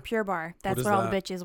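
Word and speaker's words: Pure Barre: It's spelled Barre, like Barre.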